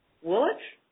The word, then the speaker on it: Woolwich: Patrick lives in Woolwich? Is that how you pronounce that? – You got it. – All right. Woolwich,